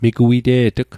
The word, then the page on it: migwite'tg: Mi'gmaq